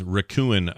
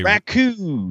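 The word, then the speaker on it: Rakuen: Raccoon. – Raccoon. – Raccoon. – But not spelled like a raccoon. It's not like a trash panda. – It's R-A-K-U-E-N is how you spell that.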